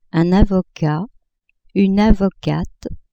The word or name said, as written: avocat-avocate